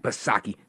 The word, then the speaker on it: Psaki: He won't have his famous handy dandy note card, and he won't have Jen Pasirkle back Psaki. Silent P, of course.